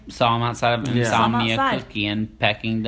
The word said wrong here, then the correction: Peking: Duck. – Yes. – Peking.